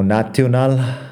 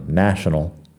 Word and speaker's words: national: Palabras como national tiene na como primera sílaba, pero luego las otras sílabas son sh, no. Na, sh, no, que tiene la vocal muy corta así, national. Si lo estás diciendo como national, pues national no es la pronunciación. National es con la schwa, la vocal corta. Suena mucho mejor.